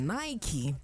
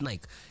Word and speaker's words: Nike: It's Nike. Nike, only Americans say it. For British, it is Nike.